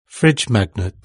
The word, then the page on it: fridge magnet: British English